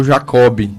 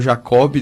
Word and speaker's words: Jacob: Então, ele escreve com B no final, e no português correto a pronúncia seria Jacó, né? Como o senhor está falando. Mas acabou que na família se pronunciou Jacob, né?